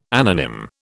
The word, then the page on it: anonym: British English